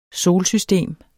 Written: solsystem

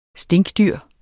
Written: stinkdyr

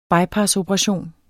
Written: bypassoperation

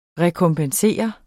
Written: rekompensere

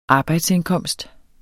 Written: arbejdsindkomst

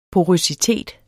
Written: porøsitet

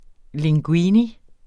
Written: linguine

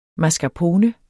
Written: mascarpone

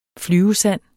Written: flyvesand

